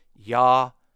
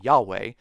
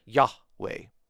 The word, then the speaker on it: Yahweh: And there is a short pause there between the two syllables. I know a lot of people just like to say Yahweh, but there is a short pause there, Yahweh. A short pause at that point.